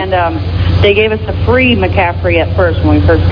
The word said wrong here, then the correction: McAfee: got it, and Hey, we're gonna teach you how to pronounce it first. It's called McAfee.